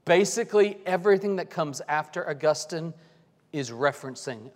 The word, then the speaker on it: Augustine: You may hear him called Augustine. Augustine is generally considered the most correct way to pronounce his name.